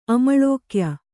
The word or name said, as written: ಅಮಳೋಕ್ಯ